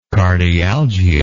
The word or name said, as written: cardialgia